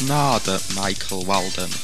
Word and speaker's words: Narada Michael Walden: As here on the label that it's pronounced Narda Michael Walden.